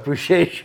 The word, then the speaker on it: Beşiktaş: From what I remember, the Galata neighborhood was really old, and then the, like, Beşiktaş area was a little bit more new. – Beşiktaş. It's actually pronounced what is it? – Beşiktaş, yes.